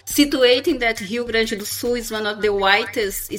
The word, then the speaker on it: Rio Grande do Sul: I don't want to butcher the Portuguese, but Rio Grande do Sul.